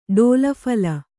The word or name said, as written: ಡೋಲಫಲ